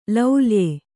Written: ಲುಲಾಯ

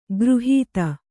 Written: ಖುರಾಹತಿ

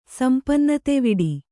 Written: ಸಂಪನ್ನತೆವಿಡಿ

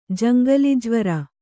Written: ಜಂಗಲಿಜ್ವರ

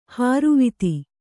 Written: ಹಾರುವಿತ್ತಿ

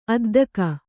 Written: ಅದ್ದುಗೆ

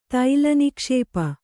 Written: ತೈಲನಿಕ್ಷೇಪ

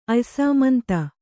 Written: ಅಯ್ಸಾಮಂತ

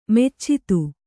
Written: ಮೆಚ್ಚಿದು

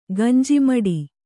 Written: ಗಂಜಿಮಠ

